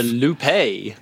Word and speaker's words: Loupé: It's called the Loupé. – The Loupé. – I like that. It's spelled like toupee, but with an L.